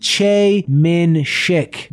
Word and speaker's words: Choi Min-sik: Thus, the actor's name sounds like Che Min-sik.